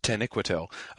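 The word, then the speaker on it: Taniquetil: As for instance, the mountain upon which Manwë and Varda live is apparently, Christopher pronounces it Tenequitil, and I did not have the stress on that syllable.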